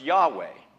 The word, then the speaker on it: Yahweh: For example, in the Old Testament, God refers to himself as I Am, which in Hebrew is pronounced Yahweh, a name that reflects God's eternal nature, no beginning and no end.